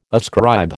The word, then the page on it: ascribe: American English